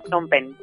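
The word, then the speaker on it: Phnom Penh: Eh, sí, sí, se pronuncia de muchas maneras. Eh, aquí le llaman Pompen.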